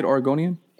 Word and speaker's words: Oregonian: Is that how you say it, Oregonian? – Yep.